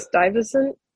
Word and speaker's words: Stuyvesant: Stuyvesant. – Stuyvesant, thank you. I always want to say Stuyvesant. Yep. I'm like, that's not it, Justin. – It is an abhorrent spelling. Yeah. – Stuyvesant University campus uptown, which is supposed to be Columbia University.